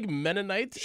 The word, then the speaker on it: Mennonite: There's a Mennonite? Did I say that correctly? Mennonite? – Sure.